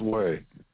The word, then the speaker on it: Hui: Today, we're going to talk about, we won't take up much of your time today, Wheeze. And that's spelled H-U-I, H-U-I, pronounced way. Actually, not we, but way, H-U-I.